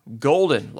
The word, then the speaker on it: Gulden: From there we go to Douglas Leroy Golden, last name is spelled G-U-L-D-E-N.